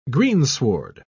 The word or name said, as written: greensward